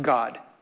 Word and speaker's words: Gad: But just so you know, his name is actually pronounced God.